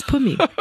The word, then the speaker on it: Pumi: Am I speaking to Muriel? I'm like, no, my name is Pumi.